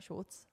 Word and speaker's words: Shultz: Shultz? Schultz? Schultz? – Is it wrong? Just say Shultz. Schultz. It's not like Schultz. You don't have to be that German about it.